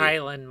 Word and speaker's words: Cillian: You're talking about Sicilian Murphy. – Kylan Murphy. – Sicilian. So that's. – I'm not joking. I think his name is pronounced with a k sound.